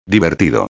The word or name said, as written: divertido